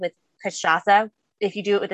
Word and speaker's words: Cachaça: Yes, it is pronounced the same way we did. So Cachaça is spelled, the spirit is spelled C-A-C-H-A, C with a little squiggly A.